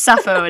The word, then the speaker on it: Sappho: but I'm pretty sure it's Sappho and Erinna.